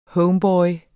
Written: homeboy

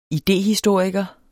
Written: idéhistoriker

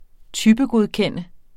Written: typegodkender

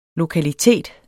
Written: lokalitet

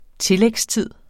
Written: tillægstid

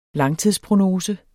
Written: langtidsprognose

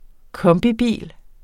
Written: kombibil

